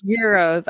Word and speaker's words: gyros: I get the gyros, like the heroes. I think it's pronounced heroes, but I say gyros. Like, I say gyros too, bro. – It's euros.